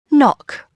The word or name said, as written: knock